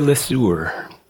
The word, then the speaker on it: Le Sueur: Jeremy was born and raised in Le Sueur. – You actually said it right. Pierre Le Sueur.